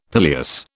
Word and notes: pileus: British English

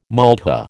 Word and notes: maltha: American English

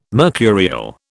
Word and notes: mercurial: British English

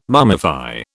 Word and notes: mummify: British English

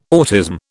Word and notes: autism: British English

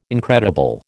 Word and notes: incredible: American English

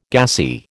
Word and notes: gassy: American English

